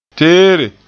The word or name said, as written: teere